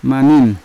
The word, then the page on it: maning: Yawa